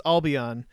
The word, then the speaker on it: Albion: I understand it's proper to pronounce the school I saw last week as Alma, and at the Britain school is pronounced as Albion.